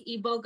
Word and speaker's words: Igbo: want everybody Go follow the Igbo goddess. And it's I G B O. Yes. yes. I, am like, she said it correctly.